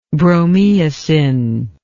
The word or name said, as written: bromeosin